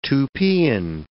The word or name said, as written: Tupian